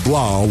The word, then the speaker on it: Blau: I'm going to say that my third and final Fulcrum football player is going to be David Blau, rhymes with cow.